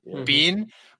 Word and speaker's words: Biehn: Biehn.